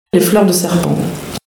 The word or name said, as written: Fleur de serpent